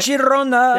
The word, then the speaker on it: Girona: Against Girona, and I'm probably mispronouncing that, right? – Girona. – There you go.